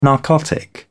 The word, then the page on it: narcotic: British English